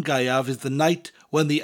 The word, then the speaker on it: Gaeaf: Then we have Galan, spelled G-A-L-A-N, pretty much pronounced as you spell it. Galan. Galan. And then we have Gaiav, spelled G-A-E-A-F. The A-E in Welsh gives you the I sound, Gai, and the F at the end of the word gives you a V sound, Gaiav.